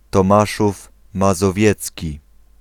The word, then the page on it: Tomaszów Mazowiecki: Pl; [tɔˈmaʂuf mazɔˈvjɛt͡skʲi]